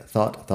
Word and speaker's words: thought: Y la GH en ningún caso se pronuncia. Así que, buy, bought, bought, catch, caught, caught, bring, brought, brought, think, thought, thought, teach, taught, taught, fight, fought, fought, seek, sought, sought.